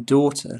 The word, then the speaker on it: daughter: In daughter, leave the GH out.